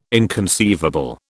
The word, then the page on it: inconceivable: British English